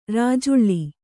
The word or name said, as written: ರಾಜುಳ್ಳಿ